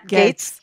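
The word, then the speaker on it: Gaetz: Kyle Cheney or Cheney of Politico says members like Matt, how do you say his name? Gates.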